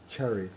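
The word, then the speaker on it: caritvā: Yeah, not charitva, but charitva. – Charitva. – It's a short A.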